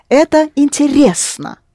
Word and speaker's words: интересно: Это интересно. Рес, stressed. Это интересно.